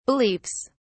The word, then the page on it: beliefs: British English